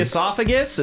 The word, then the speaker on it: esophagus: I see the esophagus. Is that how you pronounce it? – Uh-huh. – Yeah, I see the esophagus.